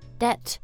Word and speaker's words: debt: 我们会说 credit card debt, 是吧？Okay, 这个债哦 debt, 但是它的拼法是 D-E-B-T. – Oh, yeah, yeah, that, don't worry about the B, guys.